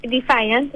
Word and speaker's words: Defiant: Sí, o sea, se pronuncia Defiant, pero casi bien.